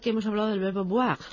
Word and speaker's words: boire: Boire con una pronunciación oclusiva sonora, B, bilabial, B, porque si lo hacemos labiodental con la V, V, sería voir, lo que significaría ver.